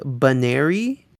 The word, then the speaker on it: Buneary: Now, this episode does kind of establish the correct pronunciation which is more like Buneary, but I might just switch to the other one as I go through because it's a little easier to say.